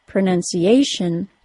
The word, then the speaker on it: pronunciation: In the word pronunciation, that means that the nun syllable receives the secondary stress.